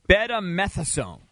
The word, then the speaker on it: betamethasone: So the drug is betamethasone. Yeah. That's how you say it.